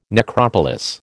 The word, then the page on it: necropolis: American English